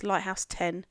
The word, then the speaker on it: Lighthouse X: It's written as Lighthouse X. It's pronounced Lighthouse 10. – That X is pronounced 10.